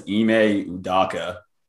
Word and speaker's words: Ime Udoka: I believe his name is Ime Udoka. Is that how you pronounce it? – Yes, sir.